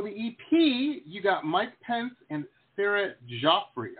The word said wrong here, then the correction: Jaffe: Hopefully I'm saying that right. Jaffrey? – Jaffe. – Jaffe, there we go.